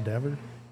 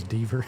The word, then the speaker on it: Dever: and you know, I'll see say Mark Dever, you know, he'll it's pronounced Dever. – Yeah, Dever. – I thought it was Deaver. – As far as I know, it's Mark Dever.